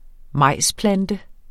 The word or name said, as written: majsplante